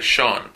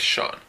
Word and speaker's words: Sean: El nombre se pronuncia Sean, por si acaso. La primera palabra es Sean.